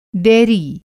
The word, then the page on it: डेयरी: Hindi